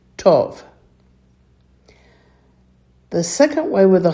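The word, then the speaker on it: tov (טוב): Notice that tov and boker both sound exactly the same as far as the long O sound.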